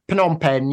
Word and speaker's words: Phnom Penh: Yeah, Phnom Penh.